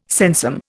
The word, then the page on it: sensum: Indian